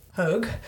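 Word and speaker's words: Haug: But Testrup's goal was cancelled out by Sophia Roman Hoag, her goal. So I just practiced that. – Hoag. – Just to... from the WSL pronunciation guide website.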